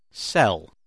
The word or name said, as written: sell